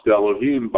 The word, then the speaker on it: Elohim: The second Yud-Kei Vav-Kei is pronounced Elohim by the reader.